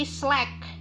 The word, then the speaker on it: Slack: aplikasi Slack aplikasi Slack kalau di Eja S L A C K bacanya slack ya kalau orang Indonesia bilangnya slack